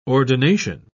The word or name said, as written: ordination